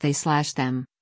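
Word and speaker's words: They/Them: is reportedly pronounced They Slash Them, as in a slasher movie.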